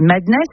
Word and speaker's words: MDNS: Ultra-violent, avec, je crois qu'on dit Madness, mais ça s'écrit M-D-N-S. – Ouais, M-D-N-S, Madness, Mad.